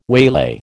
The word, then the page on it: waylay: American English